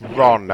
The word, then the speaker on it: Ron: Yep, it's Ron. R-O-N.